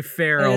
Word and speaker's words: Farrell: We're mispronouncing her name I don't know how to say Farrell, that's not right, is it? – It's Terry Farrell. – It is Farrell? – Terry Farrell.